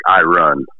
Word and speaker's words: Irun: It's a city called Irun, which is spelled like I run.